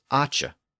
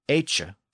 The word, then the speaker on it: acha: But the noun form was acha. So if we shift the sound of that vowel to our modern vowel sound, it would be echa for the noun.